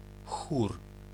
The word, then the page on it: chorus: American English